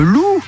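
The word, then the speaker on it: Lu: Pour gagner vos invitations, jouons avec le loup. Alors Eric Lou, ça s'écrit L-U, il est d'origine asiatique, mais bon, ça se prononce comme l'animal, le loup.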